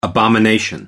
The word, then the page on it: abomination: American English